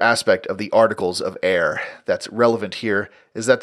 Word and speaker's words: Eyre: Uh, that's E Y R E, Eyre, as in the Circuit of the Royal Justices, and which I always want to pronounce as Erie, even though that is incorrect.